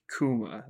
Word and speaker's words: Kuma: But anyways, he's this Barth- really Bartholomew Kuma.